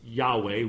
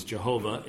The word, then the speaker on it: Yahweh: which is probably best pronounced Yahweh. We massacre it in English as Jehovah in our hymns, I think partly because it rhymes better than with anything else. But The only thing we know about the pronunciation of tetragrammaton is the one way it could not have been pronounced was Jehovah.